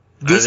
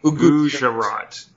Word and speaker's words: Gujarat: So when these people that were in the western state of Gujarat, how would you pronounce that? How would you pronounce that word? – Gujarat? I think you did great. Gujarat.